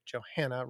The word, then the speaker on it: Johanna: And then the thing that we talked about with Johanna over the summer, I should say Johanna rhymes with Montana.